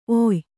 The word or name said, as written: ಒಯ್ಯಿ